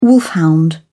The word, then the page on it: wolfhound: British English